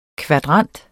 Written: kvadrant

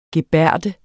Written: gebærde

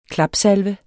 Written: klapsalve

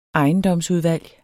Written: ejendomsudvalg

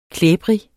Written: klæbrig

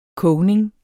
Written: kogning

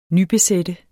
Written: nybesætte

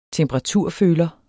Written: temperaturføler

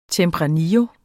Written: tempranillo

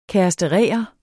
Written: kæresterere

